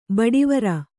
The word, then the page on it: ಬಡಿವಾರ: baḍivāra